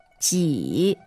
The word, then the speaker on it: 几: 几。 Ji. – J-I third tone.